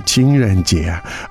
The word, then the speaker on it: 情人节: 情人节.